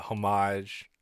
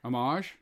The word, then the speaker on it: homage: And I think that final scene, like the homage, or sorry, how do you say it? – Homage. – Homage to Usual Suspects was fucking brilliant.